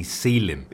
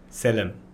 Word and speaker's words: Selim: But if you were Turkish and you pronounced it, it'd be Selim.